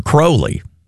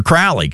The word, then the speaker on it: Crowley: Ozzy next, with Mr. Crowley, correctly pronounced Mr. Crowley, but for the song's sake and for Ozzy's sake, it's Mr. Crowley, because Ozzy said so, and that is next.